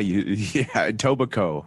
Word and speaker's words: Etobicoke: It's Etobicoke? – Yeah, Etobicoke. – Oh. – You don't spell it Coke.